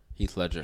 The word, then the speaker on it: Heath Ledger: I would love to see like Keith Ledger. – Keith Legend. – Ledger. Heath Ledger. – Heath Ledger. You butchered that, brother.